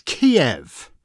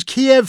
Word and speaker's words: Kiev: It's Kiev. It was Kiev for years, in the same way that we say Paris and not Paris. It's and Florence and not Firenze. There are English pronunciations of great cities and then the local pronunciations.